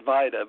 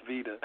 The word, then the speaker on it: Vida: It's spelled V-I-D-A, so I keep wanting to pronounce it Vita, but it's pronounced Vita.